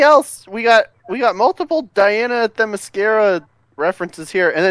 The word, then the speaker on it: Themyscira: Themyscira? – There it is.